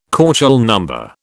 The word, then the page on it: KÔchel number: British English